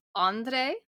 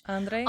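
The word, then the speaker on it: André: I always want to say it like it's Spanish. Andre. I don't know how you say it in French. – Andre. – Andre. – Andre. – Is that what it is? Andre. – Andre. – That's right.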